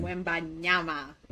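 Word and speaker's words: Wembanyama: Um, people have been hearing about Victor uh, Wimbayana. I think that's how you say his name. Wimbanyama. – Wimbanyama.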